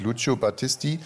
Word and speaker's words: Lucio Battisti: Es gibt es Lucio Battisti, Il mio canto libero. – Warte mal. Also ich finde, das ist relativ gut ausgesprochen, weil es heißt wirklich Lucio Battisti.